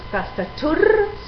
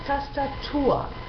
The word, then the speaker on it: Tastatur: Por ejemplo, no decimos MOCHER, sino MOCHA. No decimos TASTATUR, sino TASTATUA. Abrimos la última vocal.